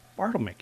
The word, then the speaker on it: Bartelmay: Bartlemy. – Can you spell that? – B-A-R-T-E-L-M-A-Y. – M-A-Y.